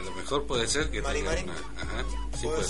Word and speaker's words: Mari Mari: Mari Mari. – Mari Mari.